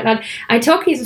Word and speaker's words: italki: iTalki.